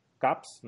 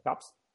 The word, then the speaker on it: KAWS: Y luego otro artista que ya sí que lo conocemos más, que es el artista urbano Caps, no sé si se pronuncia así, K-A-W-S. Sí, tal cual, tal cual. – Caps.